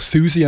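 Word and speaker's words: Siouxsie: Und die nächste Band heisst eben geschrieben, CEO and the Banshees. Und man sagt natürlich Susie and the Banshees.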